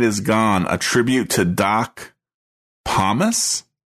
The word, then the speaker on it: Pomus: Doc Pomus.